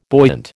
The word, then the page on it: buoyant: American English